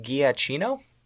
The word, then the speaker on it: Giacchino: But what's also interesting is that the music was composed by Michael, I believe it's pronounced Giacchino, who actually, you might remember, he produced the music for Medal of Honor.